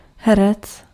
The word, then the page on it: herec: [ˈhɛ.rɛt͡s]; /ˈɦɛ.rɛt͡s/